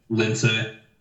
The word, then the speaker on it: Lince: Lince.